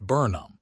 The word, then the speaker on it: Burnham: Andy Burnham, pronounced Burnham, the mayor of Greater Manchester, is one example of a leader opting for direct-to-audience content instead of live press engagement.